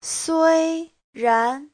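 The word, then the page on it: suiran: sui1ran2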